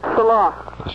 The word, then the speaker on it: Salah: Salah. – It's hello? – No, it's Salah. – Salah. You want to spell that for everybody? – Yeah, S-A-L-A-H.